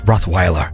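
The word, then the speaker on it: Rottweiler: Сегодня вы ротвейлер. У вас на лбу написано, кто тут главный. – Знаешь, как по-английски произносится? Ротвайлер.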